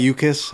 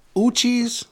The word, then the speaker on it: Uchis: Uchis is the official pronunciation, I guess.